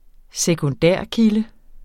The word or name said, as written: sekundærkilde